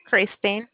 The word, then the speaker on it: Kristen: Kristen. – Can you spell it? – K-R-I-S-T-E-N.